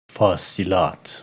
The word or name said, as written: Fasilat